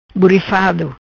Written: Burifado

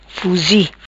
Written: Fuzi

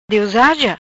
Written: Diosaja

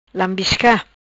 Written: Lambisca

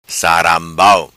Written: Sarambau

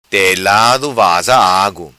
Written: vaza telado

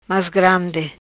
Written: Mas grandi

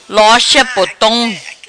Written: Lorcha Potom

